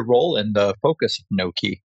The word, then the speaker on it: Nokē: In addition to leading their corporate strategy, you were leading the Nokē, it's spelled N-O-K-E, business line.